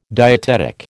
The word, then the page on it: dietetic: American English